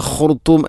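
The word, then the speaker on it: الخرطوم: പതിനാറാമത്തെ ആയത്തിൽ സനസിമുഹു അലൽ ഖുർത്തൂം, ഇവിടെ സനസിമുഹു അലൽ ഖുർത്തൂം ഓതുമ്പോൾ സനസിമുഹു ഹ എന്റെ ഉച്ചാരണം കൃത്യമല്ല, അതുപോലെ ഖുർത്തൂം എന്നതിലെ ഖ ത്വാഇന് പകരം ത. പ്രധാനമായിട്ടും രണ്ട് തെറ്റാണ് ഉണ്ടാവുക, ഒന്ന് ഖ മറ്റൊന്ന് ത്വ. ഖുർത്തൂം ഖുർത്തൂം എന്ന് തന്നെ ഉച്ചരിക്കണം.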